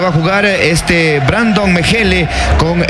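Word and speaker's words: Mechele: Me corregirá si tiene tilde por ahí, pero es Brandon Mejele, camiseta 4. – Está muy bien la pronunciación. Brandon Mejele,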